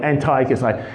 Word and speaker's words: Antiochus: his name is Antiochus, or Antiochus. Oh, I can't pronounce it. Let's call him Antiochus. That's the word, Antiochus. Some will call him Antiochus. Antiochus the Fourth.